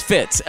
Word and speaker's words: Fitz: My name's Fitz, and I spell it F-I-T with a Z. It's not fish. It's Fitz. F-I-T with a Z right over there.